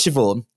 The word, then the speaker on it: Siobhan: And we'll start with Siobhan, which is my aunt's name.